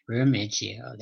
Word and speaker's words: Remiggio: Pronounce the last name properly for me there, Charlie. – It's Remiggio. – Remiggio, there. Here you go.